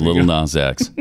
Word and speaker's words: Lil Nas X: Little Nas X. Yeah, so it is not 10. It is Little Nas X.